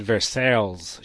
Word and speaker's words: Versailles: It's Versailles, it's French.